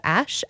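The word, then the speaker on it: Ashe: I've got one from a newer artist that I've just discovered called Ash, A-S-H-E. I think that's how you say her name.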